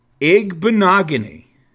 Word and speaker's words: Igbinoghene: Noah Igbinoghene? – Yeah, I think you did. – Igbinoghene.